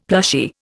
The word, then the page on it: plushy: Indian